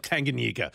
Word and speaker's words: Tanganyika: Tanganyika.